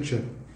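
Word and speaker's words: coepi: Nunca, que é nunca sem A, chepe que escreve COEP, só que em latim se pronuncia chepe E nunca significa agora.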